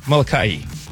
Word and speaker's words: Molokai: It's Molokai. Molokai. – Molokai. – Yeah, it's not Molokai. It's Molokai. – No, it's not Molokai. – It's Molokai. I learned that.